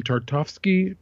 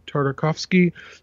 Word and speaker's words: Tartakovsky: So Genndy Genndy, I think that's how you say his name, Tartovsky, Tartakovsky.